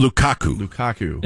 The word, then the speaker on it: Lukaku: Lukaku. – And Doku. – And he comes in and... – Not Doku and... – He scored the last star, right? – Lukaku. Doku and Lukaku.